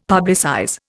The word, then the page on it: publicize: Indian